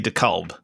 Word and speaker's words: DeKalb: Listen, I know how to say DeKalb, and they were saying it wrong on CNN, like all fucking day. And it was driving me nuts. They were not hitting the L enough.